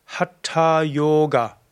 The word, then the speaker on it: Hatha Yoga: Hatha Yoga ist ein Sanskrit-Wort und du sagst Hatha Yoga. Die drei A's sind alle kurz und das O ist lang und das TH ist durchaus hören wie Ta und dabei gibst du auch die Zunge oben an den Gaumen.